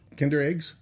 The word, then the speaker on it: Kinder: Did I say that right? Kinder eggs? – Yes, that's what the other ones are called.